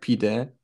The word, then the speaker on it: pide: It's called p- pide in Turkish, like pide. You you you like pronounce it that way.